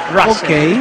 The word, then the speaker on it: Russell: No, pronunciación la real es Russell. – Russell. – Sí. Muchos le dicen Russell, está perfecto, pero la pronunciación correcta es Russell.